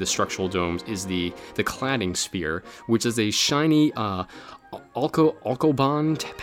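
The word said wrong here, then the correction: Alucobond: panels, I think it's called. I don't know, I'm saying it wrong. – A locobond, there we go.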